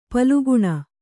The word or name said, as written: ಪಾಲಘ್ನ